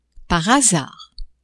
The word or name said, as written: par hasard